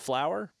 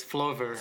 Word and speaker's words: Flover: Lucas also has a side project called, and I hope I'm pronouncing this right, is it pronounced flower? – No, it's Flover. – Flover. Okay. it's how it's spelled.